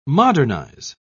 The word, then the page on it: modernise: British English